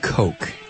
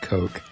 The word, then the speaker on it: Koch: Let me know that the KOCH family is pronounced Coke. KOCH is pronounced Coke.